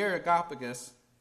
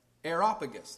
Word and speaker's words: Areopagus: The Areopagus, I said that wrong, the Areopagus, there we go.